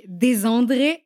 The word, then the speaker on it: Desandre: Tout de suite, ton nom se prononce donc Desandré à l'italienne,